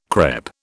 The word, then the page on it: crêpe: British English